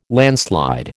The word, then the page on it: landslide: American English